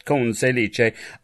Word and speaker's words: Conselice: That's how his name has to be pronounced. I just love it. Conselice.